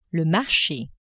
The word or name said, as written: marche